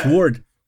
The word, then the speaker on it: sword: Nein, das heißt Sword, ihr Pappnasen. – Nee, so. Das heißt Sword. Oh. Weißt du, ein Schwert ist. Das W wird nicht gesagt und das kommt nach dem A erst, wenn du es aussprichst, aber.